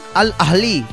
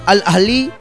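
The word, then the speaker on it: Al Ahly: luego de superar al Al ahli Al ahli así se pronuncia. No, no, se pronuncia así en serio, ¿eh? Al ahli sí, parece que me agarra tos.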